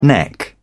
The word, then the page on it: neck: British English